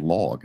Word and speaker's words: LLOG: called LLOG, and it's spelled L-L-O-G.